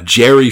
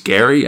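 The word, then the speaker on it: Gerry: So, yeah, so script robot Jerry Finley Day, art robot Carlos Ascara, lettering robot Steve and Jack Potter. I was corrected on the internet that I've been pronouncing Jerry Finley Day's name wrong as Gary, and so I apologize and we'll do better.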